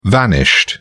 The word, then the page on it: vanished: British English